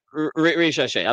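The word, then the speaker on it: Risacher: They got Zachary Risache, I think is how you pronounce his name. – Yeah, Risache.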